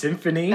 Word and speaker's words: Symfuhny: Oh, Symphony. – Nice. Symphony. – That makes sense. It's spelled S-Y-M-F-U-H-N-Y for anyone out there not versed in Symphony.